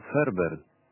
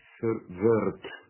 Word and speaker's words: Verwoerd: Es un apellido alemán. Verwoerd. Tiene una V corta. – Verwoerd.